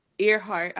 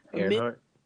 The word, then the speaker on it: Earhart: who basically helped NASA out with the mathematics, and Amelia er- Earhart. I always say her her name wrong. – Earhart.